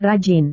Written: rajin

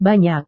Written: banyak

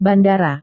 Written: bandara

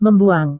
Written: membuang

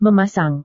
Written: memasang

